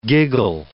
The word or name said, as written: giggle